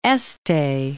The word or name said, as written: Este